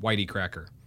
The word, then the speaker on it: YT Cracker: This is GWK Culture is Dead by Kirby Crackle and The Link by YT Cracker, pronounced Whitey Cracker. No joke.